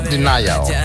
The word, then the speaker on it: D3NY4L: O sea, se escribe D3NY4L. Se pronuncia Denial.